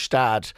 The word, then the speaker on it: Gstaad: Gstaad, but you drop the G, of course, and it's just pronounced Stad.